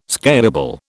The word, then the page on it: scalable: British English